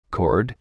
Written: cord

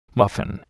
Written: muffin

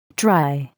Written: dry

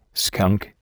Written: skunk

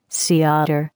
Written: sea otter

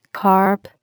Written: carp

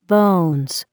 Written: bones

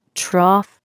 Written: trough

